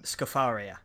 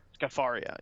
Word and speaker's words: Scafaria: And what is it, Lorraine Scafaria, how do you pronounce that? – Scafaria. – Scafaria, yeah. Which is seeking a friend for the end of the world.